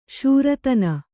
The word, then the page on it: ಶೂರತನ: Kannada